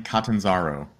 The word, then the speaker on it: Catanzaro: My name is Brian Catanzaro. It's an Italian name. – Catanzaro. – So you just pronounce it very like Latinate, you know?